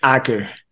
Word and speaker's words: Acker: Acker. Acker like tracker. – Yeah. Mike Acker.